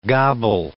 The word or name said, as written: gobble